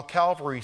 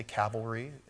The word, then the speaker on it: Calvary: And that while Calvary sounds, pretty cool and nice little word. Some people say it wrong. They say cavalry and they think, "Oh, what does this have to do with horses?"